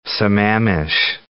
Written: Sammamish

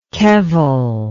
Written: kevel

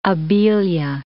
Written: abelia

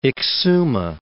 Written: Exuma

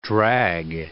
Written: drag

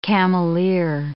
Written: cameleer

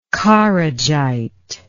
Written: Kharijite

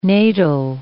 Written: natal

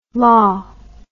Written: lah

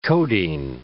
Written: codeine

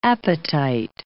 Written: apatite

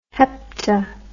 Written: hepta

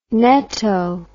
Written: neto

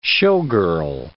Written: showgirl

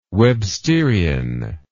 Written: websterian

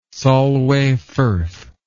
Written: Solway Firth

